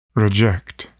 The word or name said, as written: reject